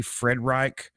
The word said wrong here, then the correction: Friedrich: passed away as well. He was. – It might be Friedrich. – Gary Friedrich. He was a co-creator of Ghost Rider, which is really cool.